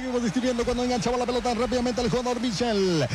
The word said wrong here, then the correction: Michael: Era Michael, así se pronuncia, dicen, es Michael.